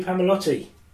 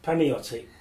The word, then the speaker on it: Palmiotti: Jimmy Palmiotti. – Palmiotti? – Palmiotti.